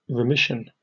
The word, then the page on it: remission: British English